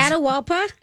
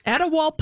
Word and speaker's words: Atahualpa: So that is how you will know him in his Atahualpa. – Atahualpa.